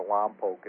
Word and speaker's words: Lompoc: Lompoc, California, or Lompoc, as some people pronounce it.